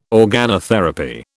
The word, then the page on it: organotherapy: British English